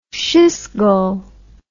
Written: Schisgal

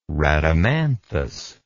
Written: rhadamanthys

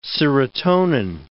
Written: serotonin

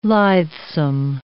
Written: lithesome

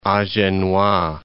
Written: Agenois

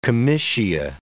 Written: comitia

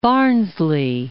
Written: Barnsley

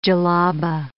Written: djellabah

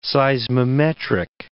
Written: seismometric

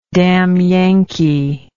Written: damyankee

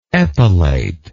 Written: epilate

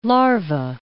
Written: larva

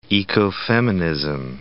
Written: ecofeminism

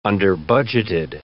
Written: underbudgeted

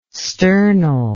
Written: sternal

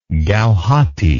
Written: gauhati

